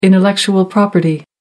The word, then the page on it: intellectual property: American English